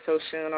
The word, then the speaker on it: Oshun: So we're going to Mother Goddess Oshun on her powerful day to see what she has to say to her daughter. Am I saying the name right, Ayana? – Yes, you are.